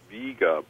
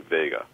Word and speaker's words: Vega: Standard pronunciation is Vega, but both forms are accepted. Most people say Vega.